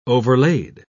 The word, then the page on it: overlaid: British English